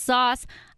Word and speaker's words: Sos: So you can find me on all social media at Shelby Sauce, S-H-E-L-B-Y-S-O-S. Yes, S-O-S is my last name. It's pronounced Sauce.